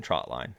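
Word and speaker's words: trotline: Trot versus trout. You know, I hear trout line more than trot line. Trout line is what people call it. And growing up, I realized that I was saying it wrong. It's not a trout line. It is a trot line. – I also said it wrong for a very long time. I said trout, T-R-O-U-T, line, trout line.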